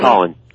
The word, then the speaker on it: Collin: Collin.